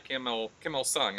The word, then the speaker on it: Kim Il-sung: And in the 1970s, in fact, still under the rule of the original guy, Kim Il-sung, am I saying that right? – Yeah.